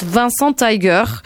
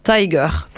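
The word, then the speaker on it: Vincent Taeger: Je dirais que ça se prononce Vincent Tiger, mais écrit T-A-E-G-E-R. Tiger.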